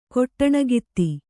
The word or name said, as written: ಕೊಟ್ಟಣಗಿತ್ತಿ